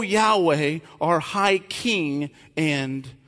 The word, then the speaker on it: Yahweh: The first use of the word Lord in this passage is the tetragrammaton, which is the word Yahweh, which is how we sometimes pronounce it, but it's YHWH. It's called Yahweh, other times called Jehovah, which is the Lord's covenantal name, the name that he revealed to Moses at the burning bush.